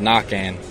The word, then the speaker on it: Naquin: Now, that leads me to your last name, Nick, is technically not I don't want to start a debate here, but Naquin is technically not the correct pronunciation. Am I right? – Right. No, it's it's, it's Cajun. It's French. So the real pronunciation is Nakan.